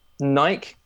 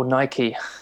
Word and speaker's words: Nike: Nike or Nike. I'm in North America now. – I know. It's like, but people pronounce it both ways, even in North America.